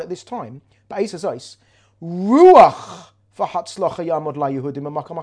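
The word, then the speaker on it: Ruach (רוח): If you spell revach, resh yud vav ches, and you take the yud out, It's spelled Resh Vav Ches. Revach, Resh Vav Ches, can be spelt, could be pronounced, I'm sorry, Ruach, Spirit.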